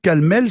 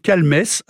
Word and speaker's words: Calmes: Et on trouve Calmel sans S, C-A-L-M-E-E, en Haute-Garonne et dans l'Hérault. Okay. Ensuite, on trouve Calmes. Alors, il faut prononcer le M-E-S. – Sinon, ça fait calme. Hein. – Voilà, mais là, je suis désolé pour les férus d'orthographe, mais on dit bien Calmes dans notre région.